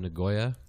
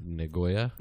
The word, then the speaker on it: Nagoya: Right down the street from my house, a place called Nagoya. – Nagoya? – Uh-huh. – Is that the right way to say it, though? – I believe so. – Did you ask them? – Nagoya.